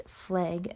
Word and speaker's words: Fleig: Now getting into the next suspect, Max Fleig, at least that's how I think you pronounce it, it's F-L-E-I-G, Fleig.